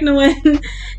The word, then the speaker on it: Nguyen: Did I say it right this time? – Eric Nguyen, yeah. – We're okay, good. Yeah. I'm getting better. Eric Nguyen.